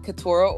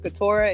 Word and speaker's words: Keturah: So on Twitter, I am at Keturah Orji, and I'll spell that again one more time. Keturah is K-E-T-U-R-A-H or G-O-R-J-I.